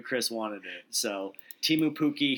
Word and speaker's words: Pukki: And Pukki is spelled P-U-K-K-I, but it's pronounced like, oh, hey, Pukki, I love you.